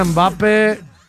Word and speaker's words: Mbappé: Mbappé. Porque tiene acento en la E.